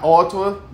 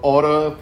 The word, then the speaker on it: Ottawa: Ottawa, né, na pronúncia britânica, ela falou Ottawa, pronúncia americana, mas eu não tinha ideia de que eu estaria morando aqui hoje.